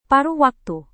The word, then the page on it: paruh waktu: Indonesian